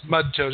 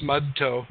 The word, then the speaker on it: Mudtoe: I'm Mud Toe. – I'm good. How do you pronounce that? – Just Mud Toe. Just Mud Toe. M-U-D-T-O-E.